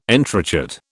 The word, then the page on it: entrechat: British English